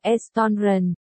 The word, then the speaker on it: restaurant: Restaurant, không phải như vậy, phải nhấn trọng âm vào âm đầu mới đúng. Triệu Tiêu đành phải há miệng ra và đọc tiếp. Restaurant, xin hỏi hai vị muốn dùng gì ạ?